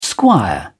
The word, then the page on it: squire: British English